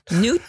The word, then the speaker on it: Nuit: Nuit Blanche again. Done this one before. Still drinking it. N-U-I-T-S.